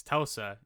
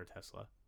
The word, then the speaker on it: Telsa: And every time I've read Descender, before I reread this, I called her Tesla every time. I forgot that it's Telsa, and it's spelled out Telsa, and she corrects him like 3 times in this book. It's Telsa, not Tesla.